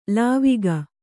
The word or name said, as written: ಲಾವಕ